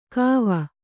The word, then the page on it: ಕೋವ: Kannada